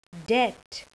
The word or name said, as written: debt